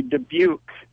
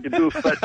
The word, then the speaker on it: Dubuque: Well, speaking of hard to pronounce, I think it's actually Dubuque rather than Dubuque. But I mean, that could be a regional thing, right?